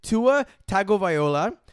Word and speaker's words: Tagovailoa: His name is Tua Tagovailoa. Tua Tagovailoa. Yet you two assholes regularly and continuously mispronounce it when you say Tua Tagovailoa. There's no tango in his name.